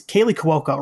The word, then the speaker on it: Kaley Cuoco: So it's got it's Kaley Cuoco, right? That's how you pronounce her name. – Yeah, from uh, Big Bang Theory is Harley Quinn herself, right? – Yep, she's Harley Quinn.